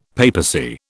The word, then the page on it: papacy: British English